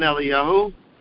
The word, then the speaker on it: Eliyah: and there are five times in Tanakh where Eliyahu's name is spelled absent of Av, such that it's pronounced Eliyah.